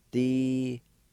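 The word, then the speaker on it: dselhin: In this word, there's a faint H sound right before the L. It's pronounced like this. D-se-l-hin.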